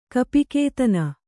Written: ಕಪಿಕೇತನ

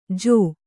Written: ಜೂ

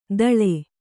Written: ತಳೆ

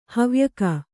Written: ಹವ್ಯಕ

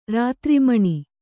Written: ರಾತ್ರಿಮಣಿ

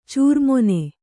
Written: ಚಾರ್ಮಣ

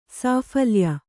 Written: ಸಾಫಲ್ಯ

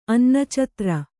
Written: ಅನ್ನಚತ್ರ

